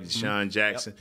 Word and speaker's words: DeSean Jackson: I'm saying that right, DeSean Jackson, yep.